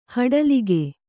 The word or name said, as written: ಹಿಟ್ಟಲೀಕ